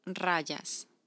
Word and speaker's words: rayas: Los tigres tienen rayas. – Rayas? – Yes. – How do you spell that? – R-A-Y-A-S.